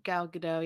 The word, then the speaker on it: Gadot: She gives me vibes of Gal Gadot, I think is how you pronounce her last name, from Wonder Woman. – Gal Gadot, yeah.